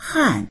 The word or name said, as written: han4